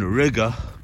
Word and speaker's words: Riga: Far more money you are saving by being in Riga, which rhymes with nigga, over Oslo.